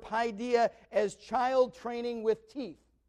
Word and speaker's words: paideia: Okay, the Greek word is paideia. Be spelled like P-A-I-D-E-I-A, okay?